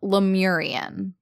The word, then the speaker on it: Lemurian: I've heard it said Lemurian, but that's Lemurian. Yeah, that's, okay.